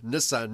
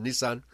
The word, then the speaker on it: Nisan: The 14th of Nisan, or I guess some people pronounce it Nissan. – Nisan.